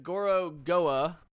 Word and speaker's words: Gorogoa: Tw- uh, Gorogoa.